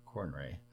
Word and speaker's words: Conroy: Suicide Squad Kill the Justice League will include Kevin Conroy. New release date. – Really? You can't say his name? – Conroy.